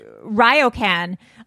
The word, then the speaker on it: Ryokan: Ryokan.